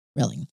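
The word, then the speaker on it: Rehling: So, Dr. C.J. Relling, I think is how you say this. It's R-E-H-L-I-N-G. Relling. – Sounds right. – Relling.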